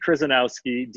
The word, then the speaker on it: Kryzanowski: D Kryzanowski, DKRYZANOWSKI, that is 12 letters.